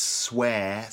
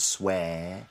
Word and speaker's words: swear: And I should probably mention the fact that the pronunciation of the word is swear, so that's air, the air sound, swear.